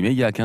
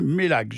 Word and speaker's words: Meilhac: Alors, c'est assez bizarre, hein, parce que on revient sur la prononciation encore de Mélac, mais ça s'écrit pas pareil. Ça s'écrit M-E-I-L-H-A-C dans ce cas-là. – J'aurais dit Méliac, hein. – Ben oui, mais eux disent Mélac.